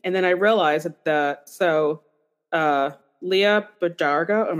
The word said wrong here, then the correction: Bardugo: Leah Bardugo, something like that.